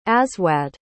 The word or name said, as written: aswad